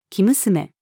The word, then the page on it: 生娘: female